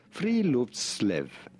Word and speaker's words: Friluftsliv: Friluftsliv. Friluftsliv. Friluftsliv. It's how it's pronounced correctly.